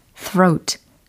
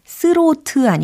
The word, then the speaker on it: throat: throat throat throat throat 스로트 아니고 throat throat throat 오 좋아요.